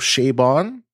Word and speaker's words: Chabon: That's a story by Sean Cochran and Michael Chabon. I think that's how you say his name.